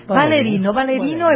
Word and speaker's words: ballerino: ¿Cómo se dice? – Ballerino y ballerina, de todo, pero han venido a bailar tango, ¿se llama el Mundial de Tango, maestro? – Sí.